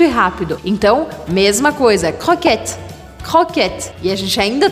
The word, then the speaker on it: croquette: Croquette, métro. Croquette, métro. Croquette, você vai fazer aqui no meio da garganta o som do R.